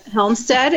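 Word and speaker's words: Hjelmstead: So my name is Megan Helmstead. There's a J in my last name, which makes it really complicated, but really it's just a Norwegian trick. So, yep, Helmstead is how you pronounce it.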